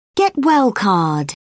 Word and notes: get-well card: British English

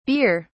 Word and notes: bere: British English

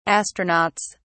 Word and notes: astronauts: British English

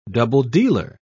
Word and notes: double-dealer: British English

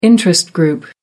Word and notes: interest group: American English

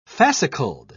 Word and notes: fascicled: British English

